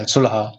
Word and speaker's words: sulha: So we call it sulha with the kha, which most people can't do. Is it sulha? How do you say it in Arabic? – Sulha? – Yeah, sulha, sulha.